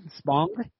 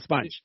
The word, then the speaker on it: Spong: Can you say his name? Spong? – Spong, yeah. – You sure it's not Sponge? – I'm positive, yes.